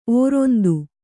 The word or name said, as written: ಓರಂತು